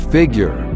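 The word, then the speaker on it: Figu3re: The artist is Figure, out of Madrid, Spain. Check him out on Twitter at Figure. But here is how it is spelled. F-I-G-U. The number 3, R-E. That's it. That's Figure.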